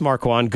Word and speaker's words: Marquand: It's Ross Marquand.